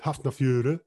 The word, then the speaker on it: Hafnarfjörður: I'm telling you, my pronunciation, lads, over the last 2 weeks, I've been working on it, and I'm not going to go with HF, I'm going with Hafnarfjörður.